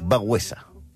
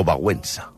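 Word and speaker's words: Wałęsa: Però què es pronuncia? Lech Bagüesa. O Bagüensa.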